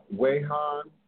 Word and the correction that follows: Wuhan: is it? Wuhan, China, where it originally emerged from.